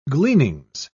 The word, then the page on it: gleanings: British English